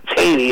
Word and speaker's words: Taney: Chief Justice Roger Taney, which is spelled T-A-N-E-Y, but it's not Taney, it's Taney, died.